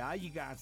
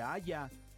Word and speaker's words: haya: No se dice aiga, se dice haya, ¿sí? Entonces, pero la gente pues insiste muchas veces en pronunciar el aiga por el haya.